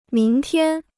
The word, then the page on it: 明天: míng tiān